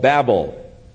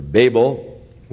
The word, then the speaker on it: Babel: Therefore its name was called Babel, or Babel, whichever pronunciation you prefer.